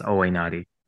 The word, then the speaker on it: Owainati: And the last name is pronounced Owainati.